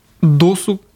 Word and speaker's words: досуг: Хороший повод задуматься про досуг. Как правильно, досуг или досуг? Досуг. Досуг.